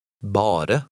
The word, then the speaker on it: bare: In number 11, remember to pronounce all the letters. Read number 11, which is a word you know. – Bare.